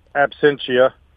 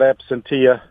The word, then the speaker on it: absentia: At the risk of sounding pretentious, it's pronounced absentia. not absentia.